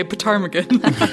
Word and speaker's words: ptarmigan: Do you know that's spelled with a P? – What? Yes, that's why I had trouble with it, because I did want to say ptarmigan.